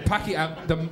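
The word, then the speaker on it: Pākehā: Pakeha, that's the Maori word, and I'm saying that correctly.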